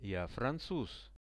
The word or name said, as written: ja francuz